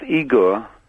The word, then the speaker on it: Igor: It's pronounced Igor. – But they told me it was Igor. – Well, they're wrong then. You know, half of Russia's called Igor.